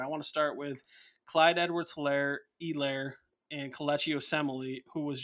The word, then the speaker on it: Edwards-Helaire: And actually, it's Clyde Edwards-Helaire, apparently. I just heard on the broadcast. It is, the H is silent, so it's Clyde Edwards-Helaire.